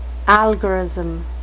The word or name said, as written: algorism